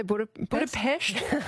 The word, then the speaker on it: Budapest: How do you say Budapest? – Very good.